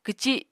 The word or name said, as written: kwci